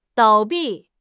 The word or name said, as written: 倒闭